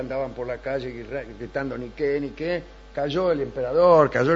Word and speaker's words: Nike: Y no se dice Nike. Se dice Niqué.